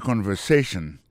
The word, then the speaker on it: conversation: Listen to the conversation. Écoutez conversation. Mot conversation, accent tombé sur avant-dernier syllabe là.